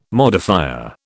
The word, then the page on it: modifier: British English